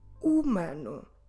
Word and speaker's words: humano: Humano. H, U, M, A, N, O. Humano. Let's go through them again and make sure you don't pronounce the H at the beginning.